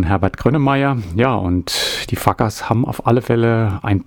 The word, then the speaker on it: FCUKERS: Also sie schreiben sich F-C-U-K-E-R-S. Ja, und natürlich ist der Bandname Fuckers.